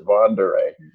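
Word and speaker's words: Von der Ahe: So that's basically why I concluded I'd pronounce it Vondere.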